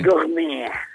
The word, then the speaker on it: gourmet: Gourmet, eller jag vet inte hur det uttalas lite här. – Gourmet. – Lite som fransé, ja.